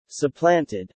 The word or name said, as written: supplanted